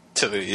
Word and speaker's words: Tui: Tui, yeah.